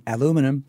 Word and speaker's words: aluminium: But the one that everyone gives the Americans a hard time for is aluminium. – Aluminum. – We would say, yeah, aluminum. – Yeah. – Which is an unusual one because all the other iniums, they will pronounce correctly, titanium. – Yes. It's not as an example, it's not titanium. – Yeah. – But for some reason, aluminium, they just drop that second I.